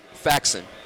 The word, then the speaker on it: Faxon: So your name, pronounce it? – Faxon. – Faxton. – Yes. – Like fax. – Yes.